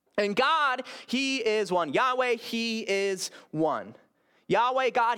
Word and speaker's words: Yahweh: God's personal name, God's personal name is, many people pronounce it Yahweh, Y-H-W-H.